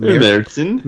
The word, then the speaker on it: Märzen: Is that how it's pronounced? – Märzen.